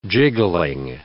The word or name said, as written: jiggling